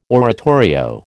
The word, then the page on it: oratorio: American English